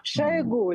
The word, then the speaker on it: Шойгу: Во-первых, надо понимать, что всякие разговоры, это, наверное, против Шойгу, Шойгу, я никак не могу понять, как его произносить. – Шойгу, Леонид, Шойгу.